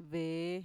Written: vee

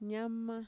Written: ñama

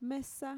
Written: mesa

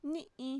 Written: ni'i

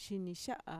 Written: shinixa'a

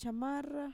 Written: chamarra

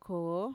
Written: koo

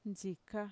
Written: ntxika

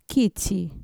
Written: kitxi

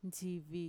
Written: ntxivi

